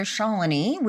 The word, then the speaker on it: Shalini: I am so excited to be here with my sister, Shalini, which her name rhymes with colony, which that's the only way other people remember how to say it.